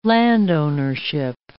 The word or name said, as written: landownership